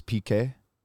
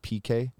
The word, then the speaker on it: Pique: Oh, so I was originally, my last name is PK, P-I-Q-U-E, and, you know, it kind of sounds like PK.